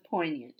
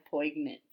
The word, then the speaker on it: poignant: The G in poignant is silent. So it's poignant. And I said poignant. My bad.